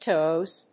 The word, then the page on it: tows: toz (米国英語); təʊz (英国英語)